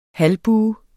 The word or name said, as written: halvbue